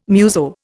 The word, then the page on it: muso: Indian